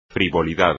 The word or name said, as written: frivolidad